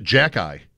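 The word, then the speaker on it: Xhekaj: His name, they pronounce it Jack Eye. – Yes. – Every time I hear it, I think somebody is starting to say Jack Eichel. I'm not yet conditioned to, like, Jack Eye.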